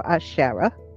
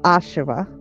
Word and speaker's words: Asherah: So those are in particular Asherah, which I'm just learning about, or Asherah. There are a few different pronunciations.